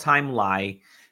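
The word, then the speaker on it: Timelie: It looks like it's spelled Time Line, kind of is, but it's called Time Lie.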